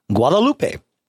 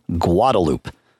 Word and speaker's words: Guadalupe: Guadalupe is another street. What do they call it in Austin? Guadalupe. Are they correct in Austin? No. Is that what everybody calls it because that's what everybody calls it? Yes. But it is an incorrect pronunciation.